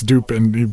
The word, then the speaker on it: Dupin: August Dupin, Dupin, or however you pronounce it, but it's Dupin because his name is literally, you know, the same root word as duped, like we've been tricked.